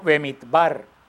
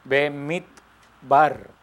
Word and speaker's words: Bemidbar: Este libro, el libro de Números, se pronuncia en hebreo Bemidbar. Bemidbar. Se pronuncia en hebreo.